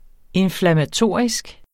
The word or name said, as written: inflammatorisk